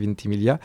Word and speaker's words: Ventimiglia: Et c'est vrai que nous, moi la première fois je l'avais lu Ventimiglia, effectivement je me suis souvenu, non, on ne prononce pas les GL, Ventimiglia.